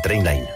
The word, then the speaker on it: Trainline: S'escriu millor preu garantit. Es pronuncia Trainline.